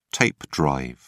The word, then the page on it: tape drive: British English